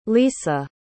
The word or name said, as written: lesa